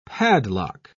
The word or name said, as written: padlock